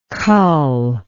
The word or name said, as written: culling